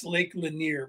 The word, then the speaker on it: Lake Lanier: That's Lake Lanier, by the way, but the computer's not pronouncing it that way.